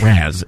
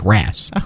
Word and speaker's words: rasp: No, it's not raz. It's rasp.